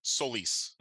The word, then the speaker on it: Solis: Is it Solis or Solis? – Am I pronouncing? Oh, it's actually Solis.